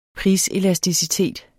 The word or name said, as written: priselasticitet